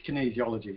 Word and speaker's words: kinesiology: So, so what exactly is kinesiology? And I hope I pronounced that right. – You did.